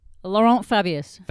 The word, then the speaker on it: Laurent Fabius: Laurent Fabius.